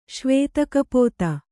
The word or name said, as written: ಶ್ವೇತಕಪೋತ